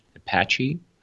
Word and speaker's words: Apache: What's important, I think those who are familiar with how most of nowadays hostings works, I mean, those that are based on Apache. I don't know if I pronounce it correctly. – Yeah, that's right.